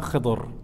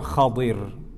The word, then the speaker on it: Khidr: So today we start with that story the story of Sayyiduna Musa alayhi salam and Sayyiduna Khidr or Khadir. There's two ways you can say his name.